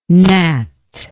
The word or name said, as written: nat